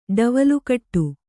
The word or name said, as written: ಡವಲುಕಟ್ಟು